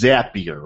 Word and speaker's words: Zapier: Is how many times have you heard people call it Zapier, which I mistakenly called it last week, but the correct way to pronounce it is Zapier, right? – Correct. Zapier makes you happier is the trick.